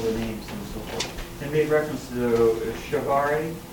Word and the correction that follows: Che Guevara: Che Guevara? – Yeah, Che Guevara, as the Christ figure.